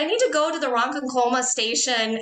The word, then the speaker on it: Ronkonkoma: there's a town, on a side note, there's a town called Ronkonkoma. And I thought it was Ronkonkoma.